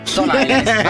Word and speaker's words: Stone Island: Questi jeans della Stone Islands. – Stone Island.